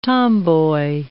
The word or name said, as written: tomboy